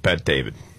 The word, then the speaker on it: Bet-David: He's talking with Patrick Bet-David. Did I get his name right? – Yeah.